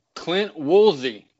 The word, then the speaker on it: Woolsey: Clint Woolsey. Did I get that right? – You sure did, man. Only about 50% of people pronounce that last name correctly, man, and you got it spot on, brother.